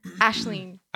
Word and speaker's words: Aisling: Ashleen.